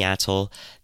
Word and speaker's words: atoll: Yeah, Atoll.